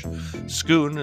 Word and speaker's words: Schoon: Schoon is spelled just like school, except with an N. That is S-C-H-O-O-N.